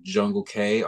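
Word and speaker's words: Jungle Cae: I go by the name of Jungle K online. That's spelled Jungle C-A-E.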